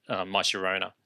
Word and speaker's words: Llorona: The only way I know how to, or not how to pronounce it, but how to rhyme it, is by thinking my Llorona. – Yes, I love it. Or Corona, my Corona. – My Corona, correct.